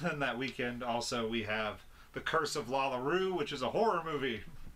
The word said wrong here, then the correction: La Llorona: La Llorona.